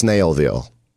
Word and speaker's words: Snellville: Snell, but it's pronounced Snailville. But it's Snellville, S-N-E-L-L.